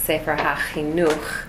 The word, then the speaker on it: Sefer HaChinuch: So the Sefer Chinuch, also known as the Chinuch, in case you ever hear it that way, the Chinuch. Really, it should be pronounced Sefer HaChinuch, but you'll hear it Sefer Chinuch or the Chinuch.